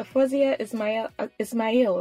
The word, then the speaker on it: Fozia Ismail: Fozia Ismail, right? – Yeah.